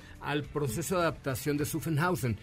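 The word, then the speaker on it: Zuffenhausen: Otra de ellas es que para su fabricación se invirtieron más de 6 mil millones de euros para adaptar esta fábrica, que no sé cómo se pronuncia, Sufen, Sufen algo, en Alemania. – Sufenhausen. – Exactamente.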